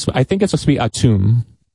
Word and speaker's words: Atum: Well, the correct pronunciation, which I'm not from Egypt, I believe is Atum.